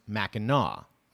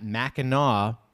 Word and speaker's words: Mackinac: There is an island in northern Michigan called Mackinac Island, pronounced Mackinac. Okay. I am just making the assumption that Mackinac Brit. See, I don't even know how to read this because it's spelled with an AC, but let me go on. So I'm going to say Mackinac Bridge, which does not go to Mackinac Island. It is also pronounced Mackinac.